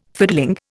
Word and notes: fiddling: Indian

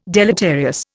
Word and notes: deleterious: Indian

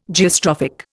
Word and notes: Geostrophic: Indian

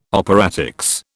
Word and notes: operatics: British English

